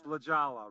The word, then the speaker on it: La Jolla: Yeah, well, yes, La Jolla, right. Yeah, wow. The J is a hoy.